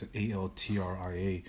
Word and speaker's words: Altria: It's Altria.